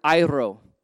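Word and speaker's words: Airo (αἴρω): And what's interesting, when I started studying this portion of Scripture, I realized that that word is actually the Greek word airo. I practiced that pronunciation for you. You got to roll the R. Airo, okay?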